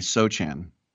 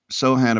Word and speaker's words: Sochan: And I've been calling him Jeremy Sochan because he's S-O-C-H-A-N. It's a silent C.